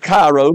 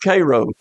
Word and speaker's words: Cairo: It's spelled like Cairo, but they call it Cairo.